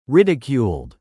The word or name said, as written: ridiculed